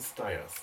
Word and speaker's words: Stiers: Ich habe mal ein Interview mit Jamie Farr, dem äh, Schauspieler, der Max Klinger gespielt hat, gehört und der hat das so ausgesprochen. Danach habe ich das geübt. David Ogden Stiers.